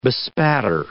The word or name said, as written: bespatter